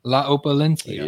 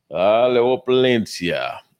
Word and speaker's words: La Opulencia: And I hope Eric can be here to listen to me pronounce this correctly. – Yes. – My Father, La Opulencia. – Yes, indeed. And you did good.